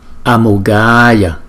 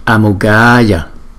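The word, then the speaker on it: Amoghāya: Cái chữ A có dấu gạch ngang này chúng ta phải đọc dài hơn là chữ A không có dấu gạch ngang này. Thành thử chữ này đọc là Amogaya, Amogaya.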